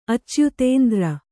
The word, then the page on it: ಅಚ್ಯುತೇಂದ್ರ: kannada